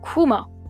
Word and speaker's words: comment: C-O-M-M-E-N-T. Right? Comment. But no, in French, comment, C-O-M-M-E-N-T, C-O-M-M-E-N-T, is pronounced Kumo.